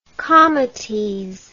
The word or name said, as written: comites